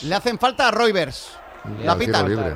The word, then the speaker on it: Reuvers: La fuente te ha dicho que es Rivers.